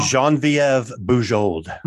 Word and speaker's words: Genevieve Bujold: The lady who was originally tapped to play Janeway, Jean Genevieve Bujold.